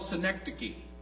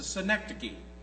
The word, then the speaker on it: synecdoche: It's called a synecdoche. That's how you pronounce that. It's a synecdoche.